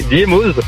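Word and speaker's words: Diémoz: Diémose, on prononce le Z? – Eh oui, on le prononce, hein, bah oui, Diémose. – Non, mais ce que je dis dans l'Isère, c'est que par exemple à Avoria, on dit pas le Z.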